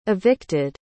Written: evicted

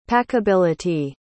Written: packability

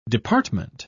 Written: department

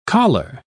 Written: choler

